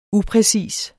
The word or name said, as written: upræcis